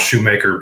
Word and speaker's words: Schumacher: Actually, I think it's pronounced Shoemaker, but I'm changing the way it's said because I like Schumacher better.